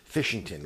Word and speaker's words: Fishington: It is Fishington, like the atomic process of fission.